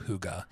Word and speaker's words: hygge: when we talked about hygge, first of all, the way it's spelled does not, the way you spell it does not sound like the way you, the way you say it.